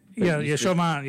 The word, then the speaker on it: Chauvin: Chauvin, yeah.